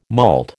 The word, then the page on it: malt: American English